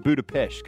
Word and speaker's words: Budapest: But it's actually pronounced Budapest because I've been there, by the way.